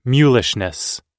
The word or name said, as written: mulishness